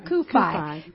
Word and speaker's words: CUFI: We pronounce that Kufi? – Kufi.